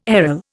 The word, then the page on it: aril: Indian